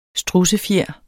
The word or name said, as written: strudsefjer